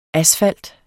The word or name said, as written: asfalt